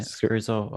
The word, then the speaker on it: Scherzo: Scherzo of dark dust? – Yeah, I say that. Scherzo of a dark dust. – Scherzo of a dark dust.